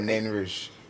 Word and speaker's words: Nain Rouge: Oh, Nain Rouge.